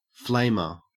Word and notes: flamer: Australian English

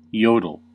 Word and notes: yodel: American English

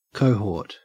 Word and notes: cohort: Australian English

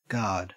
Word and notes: guard: Australian English

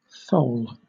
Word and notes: thole: British English

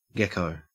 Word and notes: gecko: Australian English

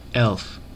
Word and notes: elf: American English

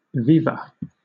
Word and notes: viva: British English

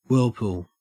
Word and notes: whirlpool: Australian English

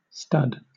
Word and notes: stud: British English

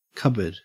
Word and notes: cupboard: Australian English

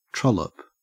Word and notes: trollop: Australian English

